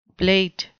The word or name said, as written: blate